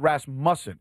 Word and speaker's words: Rasmussen: Wait, you're saying it's Rasmussen versus Rasmussen. – Emphasis in the wrong space there. – You're putting the emphasis in the wrong place. – Right. – Emphasis in the wrong syllable. – As opposed to Rasmussen. – So it's Rasmussen. – Right, Rasmussen. – Versus Rasmuffin.